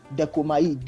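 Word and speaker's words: dekomai (δέχομαι): I hope I'm pronounced D-O-K-I-M-O-N, dokimon, which is gotten from the word dekomai. Dekomai, D-E-C-H-O-M-A-I, D-E-C-H-O-M-A-I, which means to receive or to accept.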